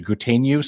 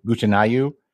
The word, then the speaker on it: Gutenyu: He actually says, and I pronounce it Gutenyu, some people say Gutenayu, but